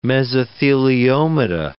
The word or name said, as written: mesotheliomata